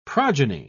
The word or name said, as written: progeny